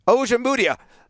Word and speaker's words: Ojemudia: And Michael Ojemudia, or how do you pronounce that? Ojemudia, I think is how you pronounce it. – Ojemudia. – Third time's a charm.